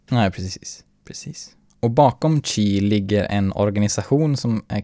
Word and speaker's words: Qi: I nuläget, alltså i dagens teknikvärld, så är Qi, eller QI uttals Qi, en av marknadens största standard för trådlös laddning.